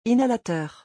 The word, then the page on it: inhalateur: French